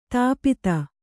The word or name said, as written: ಧೂಪಿತ